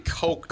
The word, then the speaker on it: Koch: K-O-C-H. – Really? – Oh. Yeah. – Well, it'd be Coke. – Coke. – Yeah, Coke. – Yeah. Well, that's how he pronounces his own name.